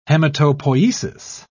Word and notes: hematopoiesis: British English

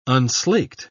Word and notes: unslaked: British English

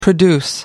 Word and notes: produce: American English

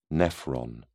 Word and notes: nephron: British English

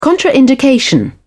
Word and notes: contraindication: British English

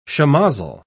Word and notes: shemozzle: British English

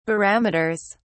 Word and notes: barometers: British English

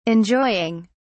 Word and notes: enjoying: British English

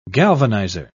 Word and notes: galvanizer: British English

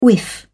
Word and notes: whiff: British English

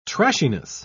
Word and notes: trashiness: British English

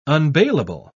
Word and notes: unbailable: British English

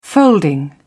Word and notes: folding: British English

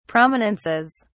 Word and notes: prominences: British English

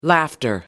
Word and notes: laughter: American English

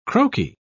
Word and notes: croaky: British English

